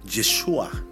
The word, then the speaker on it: Yeshua: Yeshua es la pronunciación original.